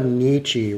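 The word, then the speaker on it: Dietschy: Sarah Nietzsche, rhymes with peachy.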